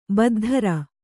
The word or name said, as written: ಬದ್ಧರ